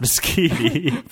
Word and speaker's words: Moschitti: Moschitti. It looks like most shitty, guys. – It's not. – But I swear to you, in another land of pasta and other carbs, it's pronounced Moschitti.